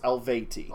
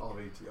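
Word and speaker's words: Eluveitie: But for the people listening at home, it is Elveitie. And it's pronounced Elveti. – Elveti, okay.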